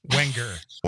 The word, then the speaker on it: Wenger: I knew either the N or the G. Is one of them silent, Alicia? I think I picked the wrong one. – You just gotta chunk it out like Wang. – Okay, Wenger. So there's the G is not pronounced.